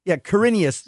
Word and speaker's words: Quirinus: The first one, St. Quirinus, I don't know if I'm pronouncing it right. – I think it's, Quirinus. Yeah.